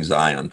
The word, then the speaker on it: Zion: His name is, I think they pronounce it Zion Williamson. Zion, Z-I-O-N.